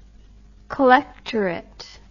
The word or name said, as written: collectorate